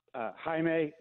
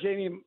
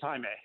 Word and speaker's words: Jaime: And my name's actually pronounced Jaime. It's very Spanish, so just I've heard Jamie my whole life, but it's Jaime.